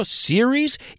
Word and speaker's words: series: ¿Sigues pronunciando series igual que serious? You can't be serious. No puedes ir en serio. Series se pronuncia series. Mientras que serious... se pronuncia serious.